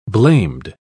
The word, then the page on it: blamed: British English